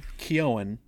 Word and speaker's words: Keoghan: Barry Keoghan, and I think I pronounced that correctly, finally. I've heard it pronounced lately. – Silent G. – Keoghan.